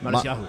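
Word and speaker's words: Matisyahu: Matsuyahu.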